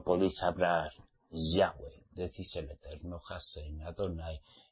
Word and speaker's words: Yahweh: Ya, pónganse en contacto, pero donde ustedes vean YHWH, Yahweh. Pronúncialo. A solas con él. Y si alguien va mesiánico a decirles, es el Eterno, no le hagan caso. Es Yahweh.